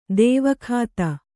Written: ದೈವಿಕತೆ

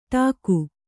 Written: ಡಾಕು

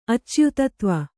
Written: ಅಚ್ಯುತತ್ವ

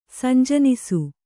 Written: ಸಂಜನಿಸು

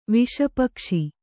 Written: ವಿಷಪಕ್ಷಿ

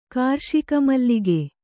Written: ಕಾರ್ಷಿಕಮಲ್ಲಿಗೆ

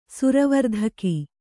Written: ಸುರವರ್ಧಕಿ

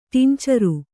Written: ಟಿಂಚರು

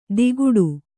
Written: ದಿಗುಡು

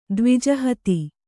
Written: ದ್ವಿಜಹತಿ

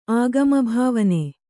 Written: ಆಗಮಭಾವನೆ

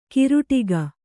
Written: ಕೇರಡಿಕೆ

